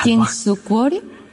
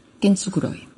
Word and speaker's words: Kintsukuroi: Eh, mira, el japonés tiene una cosa que se pronuncia exactamente igual que el español. – Entonces, Kinsukuroi. Kintsukuroi. Las letras, el fonema es igual que en el español, Kinsukuroi.